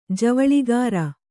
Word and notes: ಜವಳಿಗಾರ: kannada

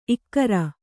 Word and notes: ಇಕ್ಕರ: ikkara

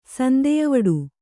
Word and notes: ಸಂದೆಯವಡು: Kannada

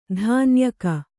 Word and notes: ದಣ್ಣಾಯ್ಕ: daṇṇāyka

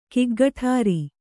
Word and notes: ಗುಗ್ಗುಟುರು: gugguṭuru

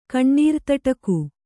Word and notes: ಕಣ್ಣೀರ್ತಟಕು: Kannada

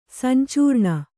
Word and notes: ಸಂಚರಣ: sancaraṇa

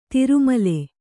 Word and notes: ತಿರುಮಲೆ: tiru male; noun

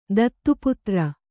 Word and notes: ದತ್ತುಪುತ್ರಿ: Kannada

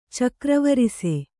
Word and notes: ಚಕ್ರವರಿಸೆ: cakra varise

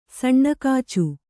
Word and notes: ಸಣ್ಣಕಾಚು: saṇṇa kācu

